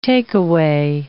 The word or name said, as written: take-away